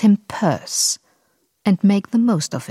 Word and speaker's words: Pierce: Man hört den ironischen Ton des Gedichts, denn Frederick Pierce, der 14. Präsident der Vereinigten Staaten und der einzige, der aus New Hampshire kam, muss natürlich nur deshalb Perse ausgesprochen werden, damit er sich wohl oder übel auf Verse reimt.